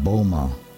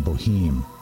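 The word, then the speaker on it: Böhme: This is from the German mystic and cobbler Jacob Bohme. Some might pronounce his name Jacob Boheme. Jacob Bohme, the German mystic.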